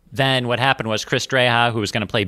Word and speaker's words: Dreja: It's a Dreja.